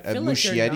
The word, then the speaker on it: Muschietti: The Muschietti cut. I don't think I'm pronouncing his name right. – Muschietti. Like Muschietti.